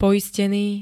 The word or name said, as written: poisteny